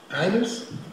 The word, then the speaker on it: Isles: British Isles, I-S-L-E-S, whatever it is, Isles? Isles, thank you.